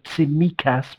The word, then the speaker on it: Tsimikas: Can I just get, you know, we've done the pronunciation of Tsimikas with the stress on the me.